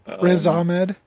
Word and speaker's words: Riz Ahmed: Am I also pronouncing his name correctly? – Riz Ahmed. Yeah.